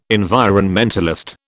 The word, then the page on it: environmentalist: British English